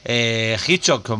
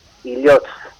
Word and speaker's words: Hilotz: No, la pronunciación adecuada es Illyots. No sé, Illyots con TZ es la pronunciación en euskera, que, bueno, no se pronuncia la H y la TZ es como una Z afilada, Z.